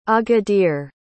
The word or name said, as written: agadir